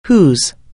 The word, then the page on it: whose: anglais